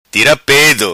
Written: Tira Pedo

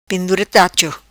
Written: Pendura Tacho

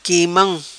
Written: quimam

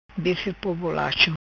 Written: Bife Po Bolacho

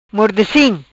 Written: Mordecim

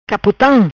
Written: Capotam

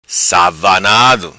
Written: Savanado

